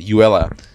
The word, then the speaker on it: Euella: So this person, the writer's name is called Uella Jackson. Do you have Uella? – Uella. – Uella Jackson. Okay. – Jackson. – Yeah. It's spelled E-U-E-L-L-A. Uella.